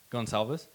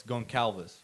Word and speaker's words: Gonçalves: Because like my next last name is actually spelled Gonçalves. Pronounced, sorry, Gonçalves, but most people are just going to read it as Goncalves, right?